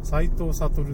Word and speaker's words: 斎藤学: あの、斎藤悟って、斎藤学と書いて悟って読んで。